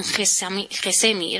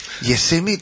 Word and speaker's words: Yessemi: Pero claro, corríamos el riesgo de gente que no fuera de aquí, que hablara el catalán... pronunciaran Gesemi, era Gesemi, no, es como, uff, no me gusta, ¿no? Vamos a ponerle, Yesemi. Vamos a cambiar esa Yesemi. G por una Y, que suena más dulce.